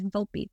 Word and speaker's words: Volpe: Everybody thinks it's Volpe. But yes, you pronounce the E. Volpe means fox in Italian.